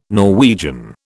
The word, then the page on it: Norwegian: British English